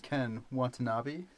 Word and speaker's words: Ken Watanabe: It stars our good friends Ken Watanabe. – Uh-huh. – Am I saying that right? – Yes.